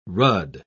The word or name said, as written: rudd